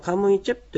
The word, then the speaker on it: カムイチェプ: ではですね、私と歌恵さんと 2 人で、それぞれカムイチェップという、このアイヌ語の単語の発音の練習をしてみようと思います。 – はい。 – このカムイチェプというこの最後ですね、ちっちゃいプですね、カタカナで書きますと。この発音がありますので、はい、カムイチェプじゃなくてチェプという発音になります。ここに気をつけたいと思います。